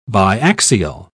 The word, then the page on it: biaxial: British English